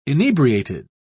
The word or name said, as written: inebriated